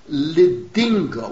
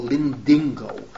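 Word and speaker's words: Lidingö: In other words, L-I-D-I-N-G-O, Lindingo.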